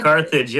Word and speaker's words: Carthage: Carthage, yep.